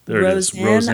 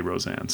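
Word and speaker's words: Rozan: Roseanne.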